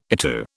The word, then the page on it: ITU: British English